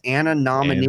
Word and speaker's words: anonymity: no, not Amity, you dummy. It's anonymity.